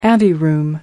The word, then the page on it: anteroom: American English